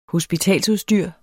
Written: hospitalsudstyr

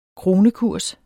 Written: kronekurs

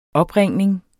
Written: opringning

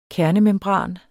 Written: kernemembran

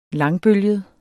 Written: langbølget

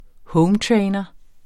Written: hometrainer

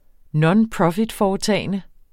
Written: nonprofitforetagende

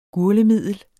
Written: gurglemiddel

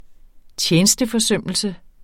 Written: tjenesteforsømmelse

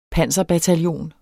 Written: panserbataljon

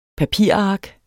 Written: papirark

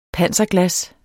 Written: panserglas

